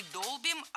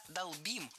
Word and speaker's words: долбим: Это удивительные красоты, а мы сидим и 4 года долбим этот дискриминант, ты понимаешь? – Не долбим, а долбим. Ударение в этом глаголе всегда стоит за корнем. Долбишь, долбит, долбим, долбят. Во всех формах ударяем окончание.